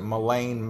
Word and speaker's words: Ghislaine: So, November 3rd, go vote for the senile, gropey, handsy, might even be on uh, I guess she decided her name is pronounced Ghislaine Maxwell. It's spelled G H, but she pronounces it Ghislaine Maxwell.